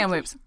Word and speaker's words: Kamloops: Kamloops.